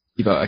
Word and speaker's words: Thibault: Thibault, okay, got it.